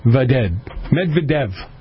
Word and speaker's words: Medvedev: Medvedev.